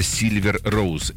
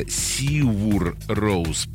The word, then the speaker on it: Sigur Rós: Но человек пишет, что группа называется Silver Rose. Нет, это вы не расслышали. Группа называется Sigur Rós по-исландски, а вообще пишется даже Sigur Rós.